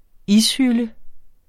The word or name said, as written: ishylde